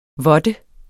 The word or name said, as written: vådte